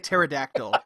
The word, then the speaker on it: pterodactyl: No, you meant to say pterodactyl. – Yeah. – That silent P really fucks you.